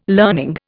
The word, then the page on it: learning: Indian